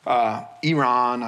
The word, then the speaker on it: Iran: And now there's a letter of lies, you know, composed, sent off 1,000 miles off to southwest Iran, Iran, I should say, the correct way to pronounce that.